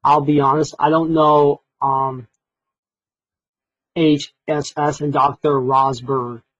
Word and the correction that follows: Rosbrook: Is that how you pronounce it? – Rosbrook, yeah, you're not Yeah.